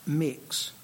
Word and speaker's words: Mx: My prefix is MX. That's pronounced mix.